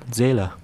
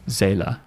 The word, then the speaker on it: Seele: So how do you pronounce that word? S-E-E-L-E. – You got it right. Zela. – Zela.